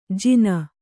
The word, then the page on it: ಜಿನ: Kannada